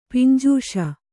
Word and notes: ಪುಂಜಿಸು: punjisu

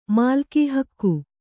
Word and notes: ಮಾಲ್ಕೀಹಕ್ಕು: mālkī hakku

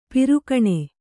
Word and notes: ಪಾರುಗಾಣು: pārugāṇu

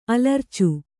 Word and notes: ಅಲರಿಚು: alaricu

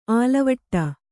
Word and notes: ಆಲವಟ: ālavaṭa

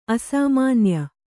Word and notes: ಅಸಾಮಾನ್ಯ: asāmānya

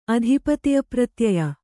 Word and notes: ಅಧಿಪತಿಪ್ರತ್ಯಯ: Kannada

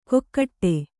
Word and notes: ಕೊಕ್ಕಟ್ಟೆ: kokkaṭṭe

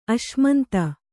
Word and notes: ಅಶ್ಮಂತ: kannada

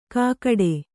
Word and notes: ಕಾಕಡೆ: Kannada

